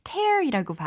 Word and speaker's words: tear: T-E-A-R. 네, tear이라고 하면 눈물이라는 명사죠. tear이라고 발음을 하면 찢다, 갈기갈기 찢다, 해체하다라는 의미예요.